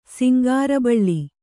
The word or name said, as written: ಸಿಂಗಾರಬಳ್ಳಿ